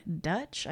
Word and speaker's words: Deutch: um, so it was directed by John Hughes, but it was initially supposed to be directed by Howard Deutch. I think is you pronounce the last name, mm-hmm, which D-E-U. is what how, the name they used for Dutch.